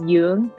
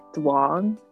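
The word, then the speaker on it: Dương: And the way you should pronounce it is Yung, because the D in Vietnamese has like two different D's. So if it has like the line, it's pronounced like an English D, but mine doesn't. So people always pronounce it as like Duong.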